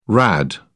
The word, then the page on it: rad: British English